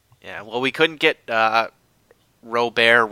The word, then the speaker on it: Robert: it's pronounced Robert, just like, you know, like the first name, American first name, Robert.